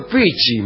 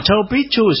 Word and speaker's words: Chau Pichu: Pero Chau Pichi, me parece. – No, Chau Pichu se dice cuando es... Y Chau Pichi. – Y Chau Pichu. Pichu, no Pichi.